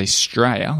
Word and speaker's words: Straya: But it's interesting because Australia they say Straya, like you said, 2 syllables.